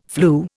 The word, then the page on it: flew: Indian